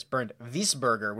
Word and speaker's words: Bernd Wiesberger: I saw that they moved, where is Bernd Wiesberger, or actually I think it's pronounced, oh, here he is, Bernd Wiesberger would be the right way to say this.